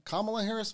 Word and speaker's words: Kamala Harris: That's all right, Kamala Harris, Vice President Kamala Harris, speaking at a 50th anniversary of Roe v.